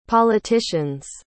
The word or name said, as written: politicians